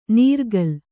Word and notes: ನೀರ್ಕಲ್: Kannada

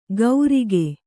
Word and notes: ಗೌರಿಗೆ: Kannada

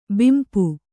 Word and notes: ಬಂಪು: bampu

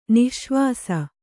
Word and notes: ನಿಃಶ್ವಾಸ: nih śvāsa

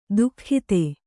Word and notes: ದುಃಖಿತೆ: duhkhite

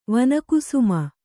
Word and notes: ವನಕುಸುಮ: Kannada

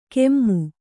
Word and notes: ಕೆಮ್ಮು: kemmu

